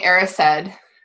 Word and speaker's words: Erised: Is that how we're pronouncing it? – Yeah. When I read these books before the movies, before other people told me how to pronounce things, I pronounced a lot of things wrong, and one of them apparently was the Mirror of Erised. – I think, yeah. We're going Erised.